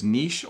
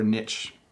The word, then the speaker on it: niche: In my conversations, people will use niche or niche, both.